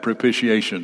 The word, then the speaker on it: propitiation: That propitiation is a big word. It does not have a T sound in it.